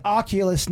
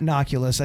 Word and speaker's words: Oculus: It's Oculus, not Noculus.